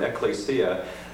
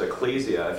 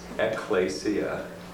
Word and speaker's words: ekklesia: which I've been trying to use the original Greek term ekklesia, which I keep hearing pronounced ecclesia. If you remember one thing here, it's ekklesia, and plural is ekklesiae, not ecclesiae. Pedantic point, but I felt the need to make it since I'm sitting here.